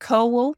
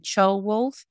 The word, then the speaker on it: Ceolwulf: So I refer to it as both, but yes, it's about Ceolwulf, which is not the right way to say it. I think you should call him Ceolwulf, but I've got used to calling him Ceolwulf, and he was Mercia's last king.